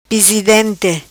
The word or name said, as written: Pisidente